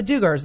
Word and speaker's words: Duggar: But the public one, I'll post stuff like about the Duggars that I want. – Right. – I don't know how to say their last Duggar. – Name correctly. I don't care. Duggar.